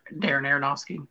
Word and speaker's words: Aronofsky: And, you know, I was sitting there thinking and watching the film, nothing against from Darren Aronofsky. Or I don't know if I'm pronouncing it right. Help me, for God's sake. – Darren Aronofsky, yeah.